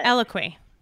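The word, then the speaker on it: Eloquii: I don't know how to say the word, which, you know, when you're like, you think a word a lot and then you have to say it out loud. It's the brand ELLOQUI. I don't even know how to spell it. – Eloquy. Eloquy. Yeah.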